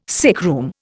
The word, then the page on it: sickroom: Indian